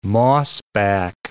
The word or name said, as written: mossback